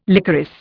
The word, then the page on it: licorice: Indian